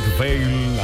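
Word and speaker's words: Whalum: Dallas en Texas, Kirk Falun, así podemos decirlo en español, Kirk Vale, así se pronuncia en su idioma.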